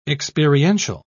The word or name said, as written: experiential